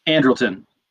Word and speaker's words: Andrelton: The other thing was they bring in, is it Andrelton Simmons? Is that how you say it? – Andrelton.